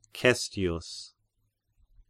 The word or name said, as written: Cestius